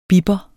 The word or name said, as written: bipper